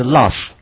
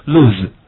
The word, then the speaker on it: lose: This one, how to pronounce? 这句话应该怎么读？ Lose 还是 loss? Okay, no, okay, are we happy? Lose.